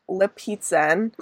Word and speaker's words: Lipizzan: Lipizzan. Like pizza.